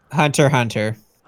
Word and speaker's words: Hunter x Hunter: Is Hunter x Hunter really called Hunter x Hunter, or is it Hunter and Hunter? How do you pronounce that fucking anime? – Hunter, Hunter. – Hunter, Hunter. So that X is just silent.